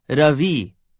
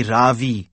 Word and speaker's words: Ravi: It's pronounced Ravi with a soft ra and a strong v. In Sanskrit, it means the sun. In America, people call me Ravi with a stress on the first syllable.